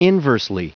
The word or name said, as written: inversely